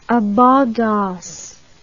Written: abondance

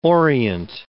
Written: orient

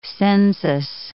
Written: census

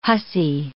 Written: hussy

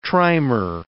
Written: trimer

